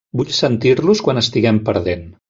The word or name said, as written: perdent